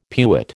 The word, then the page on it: pewit: American English